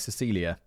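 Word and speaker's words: Cäcilia: Note here, it's spelt like Cazilia, but apparently you pronounce it like Cecilia.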